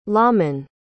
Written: lawmen

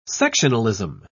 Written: sectionalism

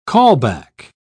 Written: callback

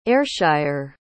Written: ayrshire

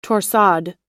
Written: torsade

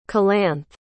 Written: calanthe

